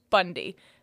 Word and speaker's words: Boundie: And this girl's wondering if she's the asshole because she doesn't want to name her kid Theodore because their last name is B-O-U-N-D-I-E, pronounced Bundy.